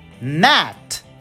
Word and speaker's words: gnat: A letra G, embora esteja escrita, ela não é pronunciada. A primeira delas é uma palavra para um tipo de mosquitinho pequenininho que se fala gnat, embora a gente escreva G N de nenê A T.